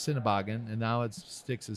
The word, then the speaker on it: Sennebogen: Sennebogen.